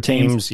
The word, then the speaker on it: Thames: Is it Thames? I think that's how you pronounce it. River Thames. – Yeah, Thames, Thames. I think Thames.